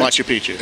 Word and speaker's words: Machu Picchu: Machu Picchu.